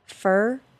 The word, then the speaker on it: for: The reduced pronunciation of the word for, which sounds like fur, will rhyme with her and stir and blur. Fur, her, stir, blur.